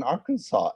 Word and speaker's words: Arkansas: And kind of fun that there's an Arkansas. Am I saying that right? – Yeah.